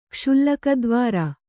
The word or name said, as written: ಕ್ಷುಲ್ಲಕದ್ವಾರ